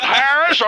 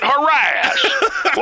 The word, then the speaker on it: harass: Is it Harris or is it harass? Well, harass is what they kept talking about.